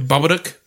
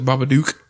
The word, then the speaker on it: Babadook: Baba Duke is how they say it. – Yeah, I thought it was pretty good. – I pronounce it Baba Duke because that's what it looks like in American English.